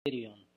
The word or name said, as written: Kurion